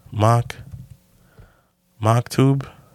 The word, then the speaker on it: Maktub: Mock tube. I don't know if I'm mock tube. It is. I guess that is the right correct pronunciation.